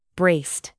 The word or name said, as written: braced